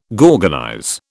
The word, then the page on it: gorgonize: British English